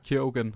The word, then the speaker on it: Keoghan: Äh, gespielt von, ich, jetzt muss ich mir im Namen helfen, Barry Keegan. Keoghan? – Ich weiß Keoghan. nicht. – Keoghan. Okay, ja, so spricht man aus.